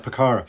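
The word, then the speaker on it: Pucará: Is that how you pronounce it, a Picara? – Yeah, that's right, Picara.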